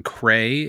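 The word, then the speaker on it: Kray: It is Cray number one from Gauntlet Comics, and Cray is spelled like prey, but with a K at the beginning.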